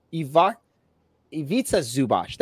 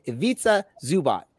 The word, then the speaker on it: Zubac: One plays for Orange County Soccer Club, and the other plays for the LA Clippers, Ivica Zubac. Ivica Zubac. That's how you say it. Ivica Zubac.